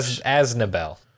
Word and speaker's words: Aznable: A-Z-N-A-B-L-E. However you spell that. – Charlemagne. – Yes. But yeah, now we have... – Aznabel.